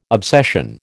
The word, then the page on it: obsession: American English